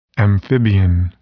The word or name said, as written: amphibian